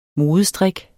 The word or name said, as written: modestrik